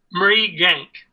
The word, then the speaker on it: Mrigang: Say the first part, first syllable. – Mrigang. – Mrigang. – Yes, that's right.